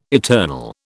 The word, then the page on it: eternal: British English